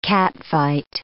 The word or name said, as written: catfight